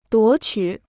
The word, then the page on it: 夺取: duó qǔ